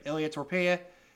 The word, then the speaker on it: Ilya Topuria: Did I say it right? Yep, Ilya Topuria.